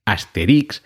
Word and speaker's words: Asterix: Yo creo que toda la vida, eh, casi todos hemos dicho Asterix, aunque lo cierto es que el nombre original en francés es Asterix, acentuado en esa I, porque se trata de jugar con la palabra asterisco en francés.